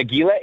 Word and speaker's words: Aguila: I'm playing Aguila 18, only one L, so you pronounce the L. If it's two L's, that's when it's silent.